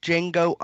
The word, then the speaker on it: Django: It's called Django Unchained. The D is silent.